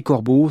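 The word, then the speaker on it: Corboz: Alors dans son Helvétie natale, dans cet arpitan franco-provençal qu'on parle dans la Gruyère, eh bien on ne prononce pas le Z final, C-O-R-B-O-Z, c'est Corbeau, ce que nous prononcerons tout au long de ces émissions.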